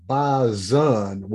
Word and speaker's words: Bison: They want to pronounce their name Bison with a Z, even though it's not spelled that way.